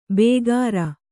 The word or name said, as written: ಬೇಗಾರ